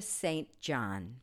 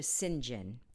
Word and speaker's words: St. John: Her name is, we pronounce it in America incorrectly, Patricia St. John. Patricia St. John. Her name is actually pronounced Patricia St. John in England, and that is how she pronounced her name, but we in America pronounce it as it's spelled, St. John.